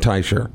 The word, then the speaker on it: Teicher: and he said Teicher. I said, like the piano duo of Ferrante and Teicher.